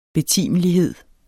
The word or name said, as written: betimelighed